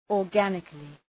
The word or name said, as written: organically